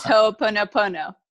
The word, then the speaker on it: Ho'oponopono: It's Ho'oponopono.